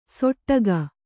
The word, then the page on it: ಸೊಟ್ಟಗ: soṭṭaga